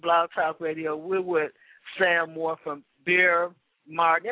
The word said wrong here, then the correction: Beryl: Beryl, like barrel. – There you go. – But it's not spelled like barrel. – It's spelled actually with the owner's mother's name was Beryl, B-E-R-Y-L.